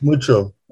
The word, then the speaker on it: Mucho: Or is it Mucho? – It's Mucho.